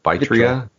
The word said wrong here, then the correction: Vitra: Vitra? You're putting an extra syllable in there.